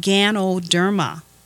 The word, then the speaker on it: Ganoderma: It is pronounced Ganoderma, G-A-N-O-D-E-R-M-A.